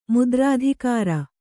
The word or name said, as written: ಮುದ್ರಾಧಿಕಾರಿ